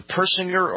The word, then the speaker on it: Persinger: and you mention a concept entitled the Persinger or Persinger helmet.